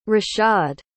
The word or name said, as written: rashaad